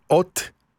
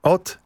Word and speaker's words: Haute: I zanim zaczniemy grać piosenkę dnia, to powiem, że ten zespół, a właściwie jego nazwę, czyta się w sposób następujący. Od z francuska, tak jak Haute Couture. Od and Freddy.